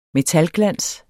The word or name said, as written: metalglans